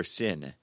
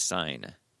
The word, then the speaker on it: Seine: coli in their River Sin, not Seine, even though it's spelled Seine, especially as the French capital prepares to welcome around 15 million tourists in the coming weeks.